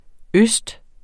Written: øst

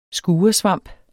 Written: skuresvamp

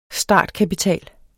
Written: startkapital

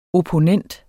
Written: OPPONENTENS

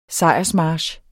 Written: sejrsmarch

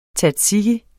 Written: tzatziki

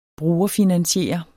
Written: brugerfinansiere

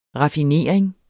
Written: raffinering

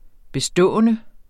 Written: bestående

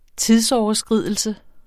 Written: tidsoverskridelse

